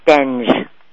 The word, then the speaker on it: Stenge: Stenge. Did I say it right? – Nope, it's Stenge.